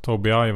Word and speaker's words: Tobii: Och tittar vi lite på vår svenska motsvarighet i galenhet i alla fall så är det ju Toby I säger man va? – Ja, no, vi har fått lära oss det efter veckans aktier att Toby I verkar vara det sättet man uttalar namnet på.